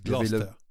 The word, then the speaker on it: Gloucester: Les Anglais disent Gloucester.